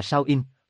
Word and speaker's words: Samhain: Halloween hoặc Samhain, phát âm là Samhain,